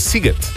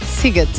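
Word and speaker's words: Sziget: Tukaj nas je nekdo od vas poučil, da se SZ v madžarščini izgovarja kot S, torej je Siget. – Siget.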